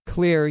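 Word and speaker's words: Xlear: That's X-L-E-A-R, but it's pronounced Clear. You don't pronounce the X.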